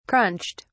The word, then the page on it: crunched: British English